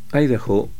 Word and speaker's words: Idaho: Idaho, Oregon o Jefferson. Estas tres palabras que se acentúan en la primera sílaba en su pronunciación